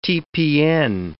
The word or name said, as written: TPN